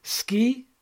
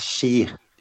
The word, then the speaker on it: Ski: Is it Ski? Is it Ska? Si? – It's pronounced as She. – She, right. Yeah. Yeah. – S-K-I, but you say She.